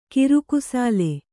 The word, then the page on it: ಕಿರುಕುಸಾಲೆ: kirukusāle